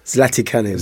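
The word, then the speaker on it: Zlatičanin: I'm probably going to butcher his name, but it's Zlat Kanin, who is the title holder. – Zlatikanin. – Yeah. Thank you, teacher.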